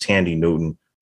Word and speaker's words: Thandie Newton: And it has Thandie Newton from I'm sorry, I think her name is pronounced Thandie Newton from Westworld.